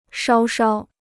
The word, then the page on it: 稍稍: shāo shāo